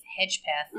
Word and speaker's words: Hedgepeth: They arrested somebody in the Faith Hedgepeth.